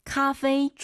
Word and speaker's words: 咖啡: 근데 발음이 좀 어려워요. 카페이가 아니라 카페이가 아니라. – 咖啡。